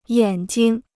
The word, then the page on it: 眼睛: yǎn jing